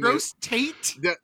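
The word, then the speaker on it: Gros Tête: That last word is T-E-T-E. – Again. – There's not an A in there. – No. – It's you might as well say like T-E-T-E.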